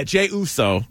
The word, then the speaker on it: Jey Uso: You had a tweet yesterday that was like, Jey Uso, am I saying that right? – Yeah, Jey Uso.